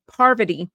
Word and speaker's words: Parvati: They picked Poverty. Parvati? I'm going to say that name wrong. It's going to sound like I'm saying poverty, and I'm not. Parvati.